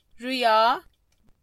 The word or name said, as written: rüya